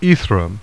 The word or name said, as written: Aethra